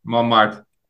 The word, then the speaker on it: Montmartre: The group arrive in, I'm going to say this wrong, Montmartre, is that how you say it? Montmartre? – Montmartre.